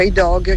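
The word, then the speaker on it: I dag: E il disco si chiama I Dog, si pronuncia I Dog, che vuol dire oggi.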